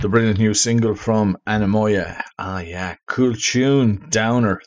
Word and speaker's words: Anemoia: And it's how you pronounce it is Anamoya, but how you spell it, A-N-E-M-O-I-A.